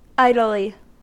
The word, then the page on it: idly: American English